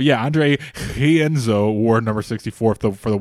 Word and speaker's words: Rienzo: I think there's a special pronunciation to this name because it's a Brazilian name. So I think people said it was technically pronounced Hienzo, maybe? – Yes, I don't know. – With the H.